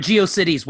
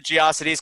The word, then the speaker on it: GeoCities: Or a GeoCities website. – Yeah. – Well, we pronounce the Geocities because it rhymed with atrocities.